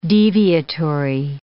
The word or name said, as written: deviatory